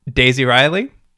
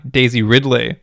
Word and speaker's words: Daisy Ridley: Daisy Ridley. Nope, said that wrong. Daisy Ridley.